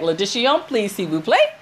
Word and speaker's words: L'addition: L'addition, s'il vous plaît. – L'addition. – L'addition. – L'addition, s'il vous plaît. – Right. The L follows into, like, addition.